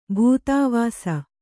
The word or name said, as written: ಬೂದಿವೂಸು